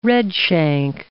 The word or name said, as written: redshank